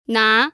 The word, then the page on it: na: 2